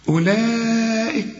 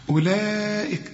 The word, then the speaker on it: أولئك: أولئك، الواو دي عليها خمسة، بيسموها حرف علة، إذا لقيت حرف مكتوب عليه خمسة كده، مرسوم عليه خمسة، إلغي الحرف ده من النطق.